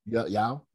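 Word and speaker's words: Yeoh: Yao? Is that how you say her name? – Yao, yeah. I think it's Y-E-O-H, I think.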